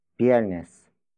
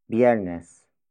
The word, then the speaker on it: Biyernes: It is the future time marker, which shows that the action will take place on Viernes. Viernes. Viernes. Viernes is spelled as B-I-Y-E-R-N-E-S.